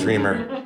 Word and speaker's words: Reimer: It's Reimer, right? It is Reimer. I pronounce it like a German, I'm sorry. Reimer.